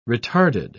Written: retarded

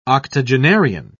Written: octogenarian

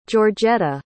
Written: georgetta

